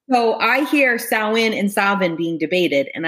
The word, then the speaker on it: Samhain: So for me, it makes sense to use the Celtic origins and the way they pronounce it is Samhain. – Mm-hmm. So Irish Gaelic says Samhain. Now let me tell you how this word is spelled. It's spelled S A M H A I N.